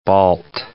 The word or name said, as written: Balt